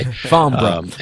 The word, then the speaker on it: Farnborough: Farnborough.